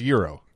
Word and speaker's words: gyro: It's spelled G Y R O, but it's pronounced Euro.